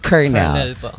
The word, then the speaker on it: colonel: Yung pag COL, yun yung abbreviation ng sa spelling noon, colonel. Pero it pronounce niyan dapat colonel.